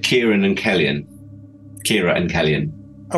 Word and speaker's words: Cillian: I'm sure he pronounced it Kellyan, which is where I took the name from.